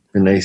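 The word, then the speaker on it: Penei: Seven, I want to say his name wrong. Pinay. Is it Pinay? Pinay? – Yep. Pinay Sewell.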